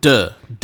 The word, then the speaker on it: Deux: D-E-U-X. Like, is that how you pronounce it? – No. Yeah. Duh. I took French and I got a C in French in high school and college, so. – I'll defer to you. Beware of Duh.